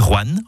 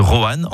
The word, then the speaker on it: Roanne: Vous avez remarqué comment je dis Roanne en une syllabe plutôt que Roanne en deux syllabes? C'est comme ça qu'on y dit dans la région.